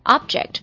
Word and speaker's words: object: 我们今天要讲的这种就是具有这种性质的一个单词，就是 Object 这个单词啊，Object，你看我发音的时候，我发的是 a object，这是美式的发音呢、啊。如果它是英式的话，是 object 是 e 音啊，object o b j e c t 这个单词意思比较多，也容易让人脑子混乱呢、啊。